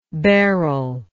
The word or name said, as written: beryline